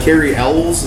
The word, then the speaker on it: Cary Elwes: Cary Elwes, is that how you say his name? – Yeah.